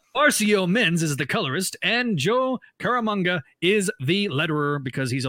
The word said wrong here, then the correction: Caramagna: Did I say it wrong? – I think the G comes before the N. – Caramagna? – Caramaga? – Yeah, Caramagna, maybe, because Italian...